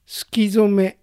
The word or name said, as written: Sukizome